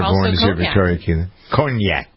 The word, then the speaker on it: cognac: You can tell I know a lot about cognac by listening to me try to figure out how to pronounce it. It's not cognac, cognac en français, right?